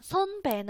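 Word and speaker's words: son be (선배): 第二个字是 b a b 啊 b，所以连起来前两个字读作 son be，son be 啊 son be 呢，其实它对应的是什么汉字呢？son 我们刚刚讲了，对应的是先，对不对？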